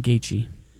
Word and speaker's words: Gaethje: Well, UFC Freedom 250 is headlined by a lightweight title unification fight between, you know, these guys, Justin Gaethje. How do you say that name? – Justin Gaethje.